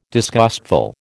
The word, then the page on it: disgustful: American English